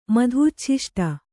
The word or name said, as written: ಮಧೂಚ್ಛಿಷ್ಟ